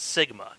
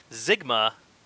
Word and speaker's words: Sigma: Now, I listened about five or six times to make sure I heard him saying Zygma and not Sigma, because Sigma is an actual word. It's a Greek letter. It's a symbol used in math. Zygma is not a word at all. So either Lobot here mispronounces the word Sigma, and they just left it in the movie, or Sigma is a Mongo word that we just do not know on Earth.